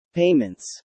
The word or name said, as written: payments